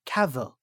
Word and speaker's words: Cavill: And then a video of him pops up and he goes, Cavill, just like travel, which is just what you said.